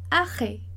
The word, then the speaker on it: arrêt: No francês, não vai mudar se tem um R ou dois R's, a gente sempre vai pronunciar r, tá? Então, aqui no caso da palavra arrêt, a gente tem dois R's e o som vai ser o mesmo. A gente vai pronunciar do mesmo modo.